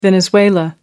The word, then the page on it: venezuela: American English